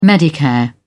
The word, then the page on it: medicare: British English